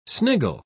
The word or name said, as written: sniggle